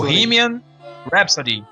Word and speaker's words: Bohemian Rhapsody: Bohemian Rhapsody. – Ah, é, tem essa porra mesmo. Né? Alguém já vai comentar aí. É Bohemian. É, Bohemian Rhapsody. Ah, se fuder, velho. É Bohemian Rhapsody, velho.